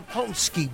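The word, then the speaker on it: pączki: The Ponsky box. It's spelled P-A-C-Z-K-I. Yeah, it's Polish. Don't ask me what the hell it means.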